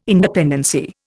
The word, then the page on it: independency: Indian